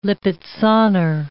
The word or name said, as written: Lippizaner